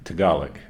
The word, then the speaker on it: Tagalog: It's pronounced Tagalog, is that right? – Mm-hmm.